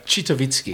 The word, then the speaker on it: Citowicki: Citowicki.